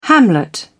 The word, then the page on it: hamlet: British English